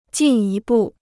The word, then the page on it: 进一步: jìn yī bù